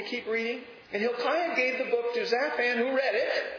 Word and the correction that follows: Shaphan: I'm sorry, I'm saying that wrong. Shaphan, who read it.